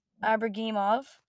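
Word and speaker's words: Ibragimov: What I think about this trade, and I'm just gonna correct every single name you said there. It's Zachary Emmond and Santeri Hatica and Timur Abragimov, and then San Jose got Fabian Zetterlund and Nikita Okorchuk and Shakir Mukhamadulin. That one I don't know how to pronounce.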